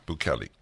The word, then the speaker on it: Bukele: So what is it? Bukele? Yeah, Bukele.